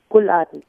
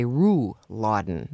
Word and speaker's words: Rouladen: Uladen, yes. – And it's spelled R-O-U-L-A-D-E-N. I was going to say Ruladen, but you don't pronounce the R so much. – Ooh, well, you roll it in your throat, so...